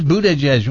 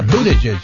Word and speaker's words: Buttigieg: Boot edges, boot edge edge. Well, same thing. – Well, there's a song actually. It says how to say his name. – Oh, there we go. Yeah, boot edge edge.